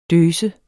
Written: døs

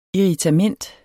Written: IRRITAMENT

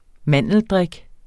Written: mandeldrik

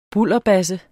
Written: bulderbasse

